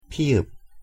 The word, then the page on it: ㅍ: Korean